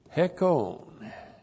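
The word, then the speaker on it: Hekon (ἑκών): Hekon. There's the letter H breathing over the word. H-E-K long O-N. Hekon.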